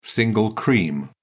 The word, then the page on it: single cream: British English